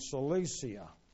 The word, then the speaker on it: Cilicia: In Galatians 1:21, he says, afterwards, I came into the regions of Syria and Cilicia. That's the way you pronounce that, by the way, Cilicia.